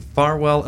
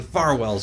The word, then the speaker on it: Farwell: up at Bedard Pharmacy on 359 Menin Avenue in Auburn and Farwell Elementary School at 110 Farwell Street in Lewiston. Yes, I had to put the emphasis on the far, so I didn't say fair.